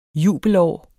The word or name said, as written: jubelår